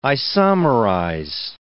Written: isomerize

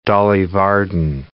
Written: Dolly Varden

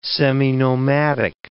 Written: seminomadic

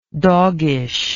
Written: doggishness

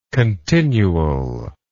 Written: continualness

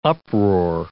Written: uproar

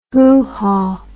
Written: hoohaw

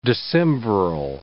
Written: decemviral

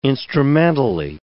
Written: instrumentally

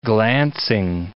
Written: glancing

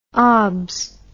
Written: obs

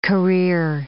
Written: career

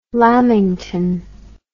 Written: lamington

